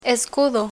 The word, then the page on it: escudo: Spanish